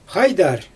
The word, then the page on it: xajdar: Xyzyl